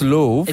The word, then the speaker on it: Louvre: It's Louvre.